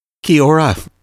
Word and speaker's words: Kia ora: Kia ora.